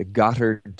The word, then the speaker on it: Gotthard: Yeah, and that's spelled G-O-T-T-H-A-R-D, the Gothard Tunnel or Gothard Tunnel.